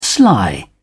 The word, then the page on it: sly: British English